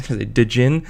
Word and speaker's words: Djinn: I also read another Comixology submit book by Natasha Van Gestel called Djinn. Djinn. It's spelled D-J-I-N-N.